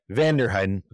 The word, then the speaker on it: Vanderheiden: but I did talk with Dr. Greg Vandenheim. Am I saying that right? Vanderheiden. Okay, I'm going to say that again.